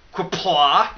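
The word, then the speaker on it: Qapla': And then something in Klingon, I'm not going to try to pronounce it, but it's Q-A-P-L-A, so Quapla. All right, so the malts, especially Turo, Vienna, and then Melonia Doiden, blah, blah, blah, blah, blah, crystal.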